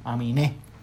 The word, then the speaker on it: Aminé: Aminé, pero él es, gringo, pero tiene el acento en la E. Aminé.